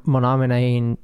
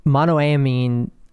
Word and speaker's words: monoamine: They are specifically not okay with MAOIs or monoamine, I'm not even saying that right, monoamine oxidase inhibitors.